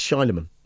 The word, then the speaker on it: Shyamalan: And he's right, it's Shyamalan.